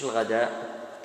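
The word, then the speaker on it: الغداة: في صلاة الصبح، وجاء في رواية الإمام مسلم في صلاة الغداء، مش الغداء بالتاء ولكنها لا تلفظ إذا كانت في آخر الكلمة، وهذا من أسماء صلاة ماذا الصبح كما تقدم معنا.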